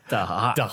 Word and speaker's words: Da'at: Da'at. – But before you look how it's spelled to understand it. Okay. – Exactly. – Everyone listening. – D-A apostrophe A-T.